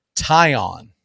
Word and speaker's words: Taillon: Taillon.